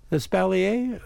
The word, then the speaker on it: espalier: If you've got a photo of that method, espalier, is it pronounced? Is that how it's called? Espalier? – Espalier, but I do not know what it means in French either.